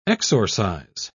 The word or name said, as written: exorcise